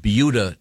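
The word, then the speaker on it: Buda: we have yet to get to sports, but we were discussing a very interesting news item from Beulah, Texas.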